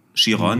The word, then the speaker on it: Chiron: Und da ist er mit seinem Bugatti Chiron, ich weiß nicht, spricht man den so Chiron, aus? Oder? Ja, ja. Chiron, mhm.